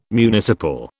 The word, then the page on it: municipal: British English